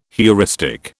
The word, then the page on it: heuristic: British English